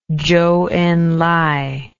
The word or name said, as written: chou en lai